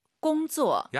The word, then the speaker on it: 工作: 일하다, 꽁쪼, 쌍지읒하지 마시고 工作. – 약간 지은 느낌으로 힘 빼고 발음하시는 게 포인트입니다. 같이 한번 따라해 볼게요.